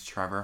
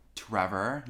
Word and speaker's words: Trevor: So first off, Cooper already introduced me, but my name's Trevor Hartman, and recently I've been like saying my name like Trevor, you know, like really enunciating the T, because I feel like I say like Trevor, like with the C-H.